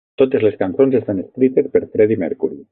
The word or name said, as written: escrites